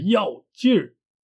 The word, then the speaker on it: 要劲儿: 今天我们要来认识的词汇是“ 要劲儿”，要不要的要，劲儿是有儿化音的发音。如果去掉儿化音的话，就变成了劲。